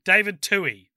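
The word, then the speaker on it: Twohy: So let's get into it, beginning with Pitch Black in 2000, directed by David Tui, I think is how you pronounce it. Tui? David Tui. That's right, yes it is.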